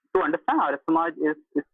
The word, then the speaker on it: Arya Samaj: So from what I understand, you belong to the Ayar Samaj, if I pronounce that correctly. And you were raised in this religion did I get the pronunciation? The Arya Samaj? – Yes, it's Arya Samaj.